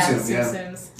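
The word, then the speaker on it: Tsum Tsum: What about Tsum-Tsums? Isn't that how it's pronounced? – Yeah, Tsum-Tsums. Yeah.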